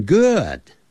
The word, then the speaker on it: good: Good，跟用书啊，一本书 b o o k book book 发音是一样的，不要念成 book good，那就不对了。